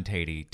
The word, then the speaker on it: Teti: I'm Real John Tatey on Instagram. I'm trying to do a little of the Instagram lately. – Do it. – Real John Tatey, T-E-T-I is how my last name is spelled.